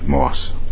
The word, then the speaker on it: Motz: I played a producer by the name of Stanley Motz, but the T is silent. Moss, eventually Motz.